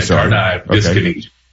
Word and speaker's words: Tardive Dyskinesia: It's Tardive Dyskinesia. Is that it? – Yeah, great job, man. It's Tardive. – Tardive. Okay, yeah, Tardive Dyskinesia.